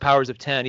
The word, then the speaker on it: Powers of X: It is so House of X and then Powers of 10, and it actually is pronounced Powers of 10, even though it says Powers of X.